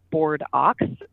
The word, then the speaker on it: Bordeaux: It takes place the fictional town of, it's spelled Bordeaux, Ohio, but it's pronounced Board Ox, which is just as delightful as it sounds.